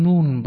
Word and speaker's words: ن: اما در این سوره مبارکه بعض از مفسرین هزام آمدن این نون را، ببینید شما در نوشتن نه نوشته شده دیگه، در خواندن میخوانیم نون، یعنی تلفظش نونه، نون نوشتن اون نه.